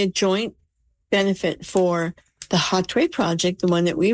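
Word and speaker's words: Hatuey: I say Hatway, even though H is silent in Spanish. It's H-A-T as in Tom, U-E-Y, the name of the great indigenous leader from the 1500s.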